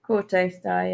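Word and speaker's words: Kauto Star: Kautostar, yeah.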